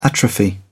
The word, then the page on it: atrophy: British English